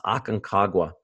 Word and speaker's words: Aconcagua: And it's actually pronounced Aconcagua.